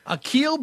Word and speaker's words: Akil: His first name, A-K-I-L, that's Akil. – Akil Badu, yeah. – Badu, B-A-D-D-O.